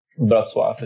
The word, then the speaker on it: Wrocław: So my whole Polish family is outside of Wrocław, is how it's pronounced in Polish.